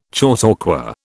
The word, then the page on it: chautauqua: British English